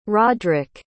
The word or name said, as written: rodrick